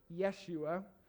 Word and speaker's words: Yeshua: And there's no name more profound in the Bible than the name Joshua, pronounced Yeshua, which literally means God is salvation.